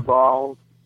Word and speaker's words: Baal: I believe it's pronounced Baal or Baal stone. – Yeah, Baal. Yeah, Baal or Baal, yeah.